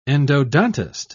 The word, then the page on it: endodontist: British English